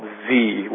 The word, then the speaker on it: Xe: It's called Z, which is the pronunciation of, at least according to them, the letters XE, which is the way they've rebranded their company.